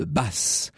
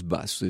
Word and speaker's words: Batz: C'est le baron de Basse. Alors, qui est-il? Ça s'écrit B-A-T-Z. Qui est-il? Mais on prononce Basse, ça fait partie de ces curiosités de l'histoire.